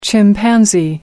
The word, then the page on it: chimpanzee: American English